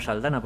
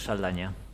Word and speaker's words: Saldaña: Y allí la presentan como Saldana, porque los pobres no tienen la ñ ni la saben pronunciar. Así que vamos a dejarlo como Saldana, aunque de origen es Saldaña.